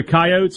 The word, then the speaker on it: Coyotes: Don't get it twisted. The South Dakota Coyotes are pronounced with two syllables.